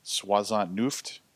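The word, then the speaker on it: soixante-neuf: So-and-so, meanwhile, was on all fours next to me, soisant nuft over her victim.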